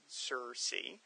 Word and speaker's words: Circe: We've got Kronos and Circe, which is Circe.